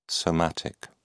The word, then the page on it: somatic: British English